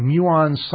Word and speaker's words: muon: And the website, the compact, how do you say this word? M-U-O-N? – Muon.